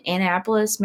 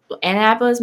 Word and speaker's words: Annapolis: She was following her heart, and her heart led her to the United States Naval Academy in Annapolis, Maryland. I don't know if I said that right. – You did. – Okay. Annapolis, Maryland.